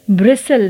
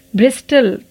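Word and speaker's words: bristle: ब्रिसल ना कि ब्रिस्टल तो टी साइलेंट हुआ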